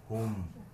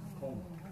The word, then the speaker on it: Hum: Rinpoche, most of the Vietnamese Buddhists, perhaps even Chinese, they pronounce the syllable hum as hum, H-U-M. So what is the correct way to pronounce it? – Hung. – So H-U-N-G instead of H-U-M.